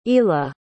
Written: eala

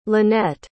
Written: lynette

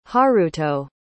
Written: haruto

